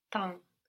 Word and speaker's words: temps: But then we have Tem. No, if I say it how it's written. But there again, we have an M on the end of the syllable. So how would that sound in French? – Temps.